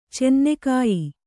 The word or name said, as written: ಚೆನ್ನೆಕಾಯಿ